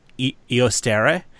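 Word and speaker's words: Eostre: Eostere, as Ian McShane pronounces it in the show, the legendary goddess of Easter.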